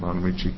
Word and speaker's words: Bonamici: Chairman McPherson, Representative Bonamici.